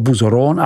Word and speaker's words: Bouzeron: Zdejší vína jsou připravována v jakostním statutu Grand Ordinaire AOC, vyslovuj Grand Ordinaire AOC, a prodávají se pod označením Bouzeron AOC, vyslov Buzoron AOC, s roční produkcí 350 tisíc lahví.